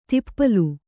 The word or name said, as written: ತಿಪ್ಪಲು